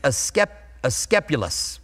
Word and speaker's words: Asclepius: It's Asclepius. I worked through it. Will I say it right again? Probably not. Asclepius.